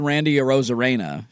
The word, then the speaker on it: Randy Arozarena: They added Randy Arozarena, correct pronunciation.